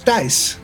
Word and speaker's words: Thijs: The pronunciation is Thijs. So that's like T-Y-S.